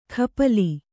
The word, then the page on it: ಖಪಲಿ: kannada